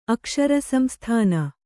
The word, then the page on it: ಅಕ್ಷರಸಂಸ್ಥಾನ: kannada